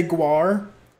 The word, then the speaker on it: GWAR: I love the fact that the lawyer didn't say Guar, which is how you say that band's name.